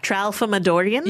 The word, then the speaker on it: Tralfamadorian: Yes, Trafalmadorians. Am I even saying this correctly? – I kept stumbling. Trafalmadorian? – Well, I mean, I called you that. Tramalfadorian? – Tralfamador is the planet.